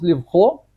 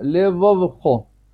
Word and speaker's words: левовхо: Удвоенное бет, нужно его выделить, что оно то второе тоже его произносить, чтобы не получилось левхо, левов, поэтому я выделяю его второе, левовхо.